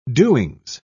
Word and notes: doings: British English

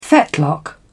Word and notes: fetlock: British English

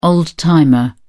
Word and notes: old-timer: British English

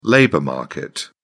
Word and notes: labor market: British English

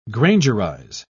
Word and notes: grangerize: British English

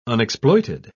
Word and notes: unexploited: British English

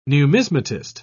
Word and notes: numismatist: British English

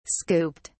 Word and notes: scooped: British English

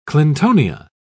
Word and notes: clintonia: British English

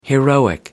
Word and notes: heroic: American English

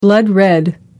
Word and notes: blood red: American English

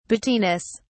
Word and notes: battiness: British English

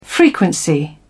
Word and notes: frequency: British English

